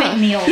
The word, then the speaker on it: fentanyl: fentanyl.